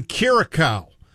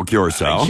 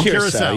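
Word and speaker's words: Curaçao: Loyal listener on vacation checking in from Curacao. Thunder up. – Oh, Curacao. – Curacao. Yeah, sorry. Yeah.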